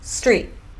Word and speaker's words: street: Essa palavra rua é street. Street. Não é street, tá? Então tira E do começo e I no final.